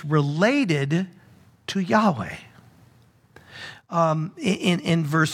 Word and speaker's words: Yahweh: Well, that's the English translation of Yahweh, or actually the four consonants, WH, thought to be pronounced Yahweh.